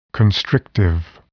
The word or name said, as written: constrictive